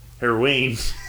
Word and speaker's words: heroine: Heroine. – Is it pronounced the same as the drug? Is it heroin? – Yeah, heroin.